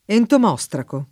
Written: entomostraco

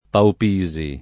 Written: Paupisi

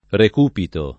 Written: Recupito

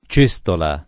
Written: cestola

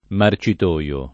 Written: marcitoio

